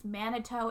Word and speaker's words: Manitou: It's called, in that book, it's called the, I think it's pronounced Manitou. It's M-A-N-I-T-O-U.